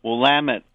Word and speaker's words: Willamette: It's nice to hear Willamette pronounced the right way.